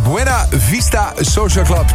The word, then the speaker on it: Buena Vista Social Club: Want ik heb op de radio dit wel eens verkeerd uitgesproken, hoor. De Social Vista Buena Vista Social Vista Buena Social Club. Maar het is dus Buena Vista Social Club.